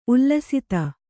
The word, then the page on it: ಉಲ್ಲಸಿತೆ: ullasite